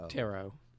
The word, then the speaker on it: Otero: Julie O-T-E-R-O. – Otero.